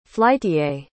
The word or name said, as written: flytier